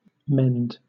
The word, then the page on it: mend: British English